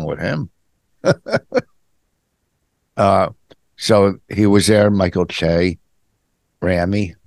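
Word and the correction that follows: Ramy: How do you say his name? – Rami.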